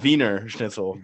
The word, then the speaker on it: Wiener Schnitzel: You said Wiener schnitzel, but you know, a Wiener schnitzel. – That's passable pronunciation. We'll give you that. – Sounds a little bit more Russian, but yeah, Wiener Schnitzel.